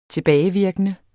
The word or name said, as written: tilbagevirkende